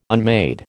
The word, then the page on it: unmade: American English